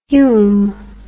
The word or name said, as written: Hulme